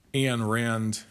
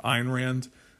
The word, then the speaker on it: Ayn Rand: I'm not, I like Ayn Rand, sorry, everyone pronounces it Ayn Rand, so I guess that's how they say it. I like Ayn Rand.